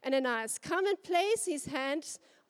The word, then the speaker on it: Ananias: Now, I'm going to pronounce it Ananias. I've got the mic, and I'm going to say Ananias. It is Ananias.